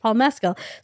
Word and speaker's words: Mescal: I do know that his name is pronounced Paul Mescal.